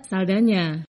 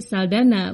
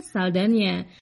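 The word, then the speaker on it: Saldaña: Dalam bahasa Spanyol yang umum di Amerika Latin, huruf N dengan tanda berliku diucapkan seperti nye. Karena itu Saldana dengan huruf N yang disertai tanda berliku seharusnya dibaca Saldana. Sayangnya Hollywood lebih mengenal artis ini sebagai Saldana, bukan Saldana.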